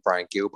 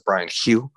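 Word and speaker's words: Bryan Gil: I'm going to call him Brian Gill, but I think you pronounce it Brian Hugh, but I'll call him Gill.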